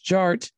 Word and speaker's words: Jart: And my name is Jared, aka DJ Art, with two T's for a double dose. That tink tink, the D is silent, so it's just Jart.